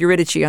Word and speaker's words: Eurydice: And she said, well, if it's Italian opera, you can say Eurydice. If it's Greek, you say Eurydice. So we went with Eurydice on this one.